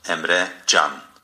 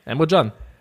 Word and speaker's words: Emre Can: Ich habe nämlich eine Aussprache von Emre Can gefunden. Willst du hören? – Ja. – Okay. Warte, Mikrofon ausrichten. – Emre Can. – Emre Can. – Hast du recht, Emre Can.